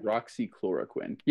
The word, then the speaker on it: hydroxychloroquine: Hydroxychloroquine. Did I say that right? – Hydroxychloroquine.